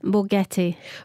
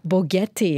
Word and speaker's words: Boquete: B-O-Q-U-E-T-E. And how do I pronounce that? – Bogete. – Bogete, yeah? – Yes.